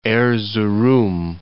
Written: Erzurum

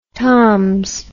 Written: toms

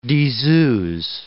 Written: diseuse

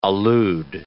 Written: allude

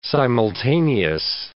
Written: simultaneous

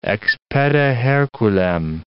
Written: ex pede Herculem

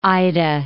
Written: Mount Ida

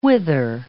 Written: whither

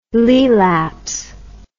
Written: Laelaps

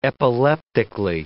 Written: epileptically

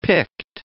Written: Pict

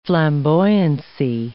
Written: flamboyancy

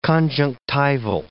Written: conjunctival

